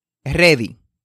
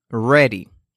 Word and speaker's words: ready: algunos ejemplos de pronunciación incorrecta cuando utilizamos la R en español es ready, ready, eso es incorrecto. La forma correcta es ready. Ready. Nótese que la R no toca ninguna parte de la boca, es decir, no toca ni el paladar superior ni el inferior al pronunciarse la R.